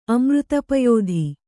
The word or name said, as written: ಅಮೃತಪಯೋಧಿ